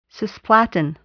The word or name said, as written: cisplatin